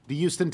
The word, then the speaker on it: Houston: I basically pronounce it U-S-T-O-N. – Yeah, like herbs. – Yes. – They're not herbs. They're herbs. And by the way, in New York City, it's Houston.